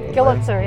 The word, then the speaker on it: Gillitzer: Gillitzer, yes.